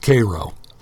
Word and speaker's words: Cairo: Now, it's spelled as Cairo, like the city in Egypt, but they call it Cairo.